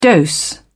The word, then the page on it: dose: British English